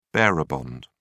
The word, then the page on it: bearer bond: British English